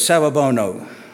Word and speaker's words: Sawubona: She said it was Sawubona, which means, I see you.